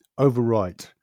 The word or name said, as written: overwrite